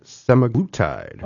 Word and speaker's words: semaglutide: semaglutide.